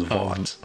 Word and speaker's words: Vought: I think it might be spelled like John Voight's name, but I think they pronounce it in show as Vought.